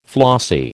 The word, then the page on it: flossy: American English